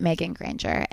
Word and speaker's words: Meagan Grainger: I am on Instagram at Megan Granger, and you spell my name G-A-Y. G-A-Y. my name is spelled M-E-A-G-A-N-G-R-A-I-N-G-E-R. – Perfect.